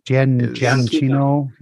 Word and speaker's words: Giacchino: Giacchino, yeah.